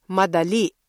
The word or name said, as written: madali